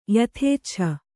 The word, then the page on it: ಯಥೇಚ್ಛ: yathēccha